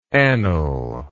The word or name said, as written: anil